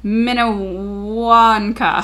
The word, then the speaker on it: Minnewanka: How do you pronounce that? – No. – Minnewanka. – Let me see it. Minnewanker. Minnewanka. – Sure. – Yeah. – Minnewanka. Is a mountain with a high, sharp peak shaped like a tower.